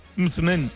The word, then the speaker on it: msemen: Pero una palabra complicada decía es M-S-E-M-E-N. Mitzmen, mitzmen, ¿vale? Y se pronuncia como lo ha hecho Hakima.